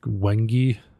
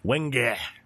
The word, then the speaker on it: Wenge: I think some people are pronouncing it like Wengi. – Wenge. – Yeah, and it's like, no, that sounds terrible.